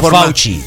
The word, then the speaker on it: Fauci: Se pronuncia Fauci, se pronuncia Fauci, Fauci, Fauci, Fauci, sí, ¿Cómo dijimos? – Fuchi.